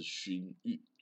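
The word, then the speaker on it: 荤粥: 它这个字呢是破音字，看起来呢是荤素的荤，跟呢早餐的那个粥啊，吃粥的那个粥，哦，但是它的读音是勋玉，哦，这个勋玉呢，史史记记载啊，在西元前二零七零年哦，大禹建立了夏王朝，有没有夏禹？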